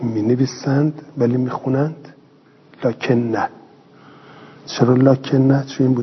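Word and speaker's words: لٰكِنَّا: یه کلماتی توی قرآن کریم هست که رسم الخطش یه جوره و تلفظش یه چیز دیگه. این توی سوره کهف می نویسیم لکن نا، می نویسیم، ولی باید بخونیم چی؟ لکن نه، لکن نه هو الله ربی.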